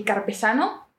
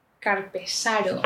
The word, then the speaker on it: carpesano: ¿y el carpesano? Dice, ¿carpesano? Carpesaro. No se dice Carpesaro, yo. No, es Carpesano.